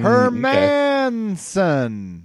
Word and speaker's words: Hermansson: hermansson okay. that's better that's the one you're supposed to go with apparently herman ssson because I think of it as Hermanson, but then you have the double S's.